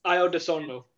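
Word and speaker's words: Ayo Dosunmu: Io DeSunmu.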